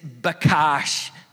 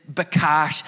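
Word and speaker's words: bakash (בקש): The Hebrew verb to seek is bakash, B-A-Q-A-S-H. That's how you would spell it phonetically. The Hebrew verb to seek is bakash, and it has this strong meaning of being fully focused and engaged in this search.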